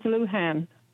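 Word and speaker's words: Luján: Now, how do you pronounce the governor's middle name? – It's Lujan.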